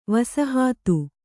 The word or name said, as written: ವಸಾಹತ